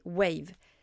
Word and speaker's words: Wavve: Alors pour faire ça, je vous conseille d'utiliser Wave. Cette fois, ça s'écrit W-A-V-V. E. Allez savoir pourquoi ils ont écrit ça comme ça, mais en tout cas c'est comme ça que ça s'écrit.